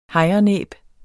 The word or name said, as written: hejrenæb